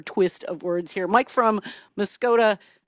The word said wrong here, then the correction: Muscoda: Maggie, that's pronounced Muscoday. – Muscoday. I get this wrong every time, Mike. You keep correcting me, and one of these days I'll get Muscoday right. Thank you for your correction, friend. Go ahead. – Well, I think that might be our way of pronouncing it, but maybe the Indians, the Native Indians, pronounced it Muscoda.